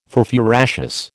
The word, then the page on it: furfuraceous: American English